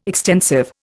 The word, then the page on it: extensive: Indian